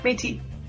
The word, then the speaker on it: Métis: Did I say that right? Is it Métis? – Yeah, Métis.